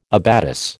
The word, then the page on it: abatis: American English